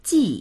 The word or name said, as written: ji4